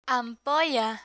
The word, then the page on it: ampolla: spanish